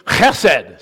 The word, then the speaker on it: chesed: Loving kindness, the Hebrew word is distinctive. It's chesed. Say it with me. Chesed. If you're not raising phlegm, you're not saying it right.